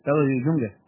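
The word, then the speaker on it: Traudl Junge: ¿Cómo se pronuncia este nombre? Ayúdame. – Traudel Junge. Traudel Junge.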